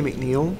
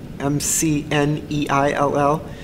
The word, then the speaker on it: McNeill: Well, if they want to, um, if they want to follow me, um, I you can you can Google Maggie McNeill, uh, and the last name is spelled uh, M C N E I L L.